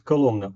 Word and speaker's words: Коломна: Город Коломна. Ударение на второй слог? – Хороший вопрос.